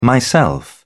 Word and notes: myself: British English

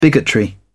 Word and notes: bigotry: British English

